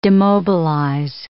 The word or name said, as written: demobilize